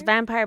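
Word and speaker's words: Vampyr: It's Vampire, but it's spelled P-Y-R. – Vampire. It's still Vampire.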